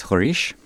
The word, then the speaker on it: Roriz: That's Roriz, but in Portuguese it's Horish.